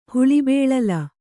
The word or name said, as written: ಹುಳಿಬೇಳಲ